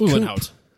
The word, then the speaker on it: Uhlenhaut: Uhlenhaut? – Sure. – Uhlenhaut? – Uhlenhaut.